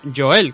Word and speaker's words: Joel: Que hasta Dan Hardy tuvo que salir a pronunciar Joel, que es como se pronuncia.